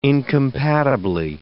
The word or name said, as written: incompatibly